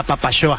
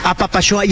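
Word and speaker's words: Apapaxoa: A Papachoa se escribe con X, se escribe a Papaxoa, pero se pronuncia a Papachoa. Como a Papacho, ¿eh? No se sientan mal por decir la X como CH, ¿no? – A Papachoa.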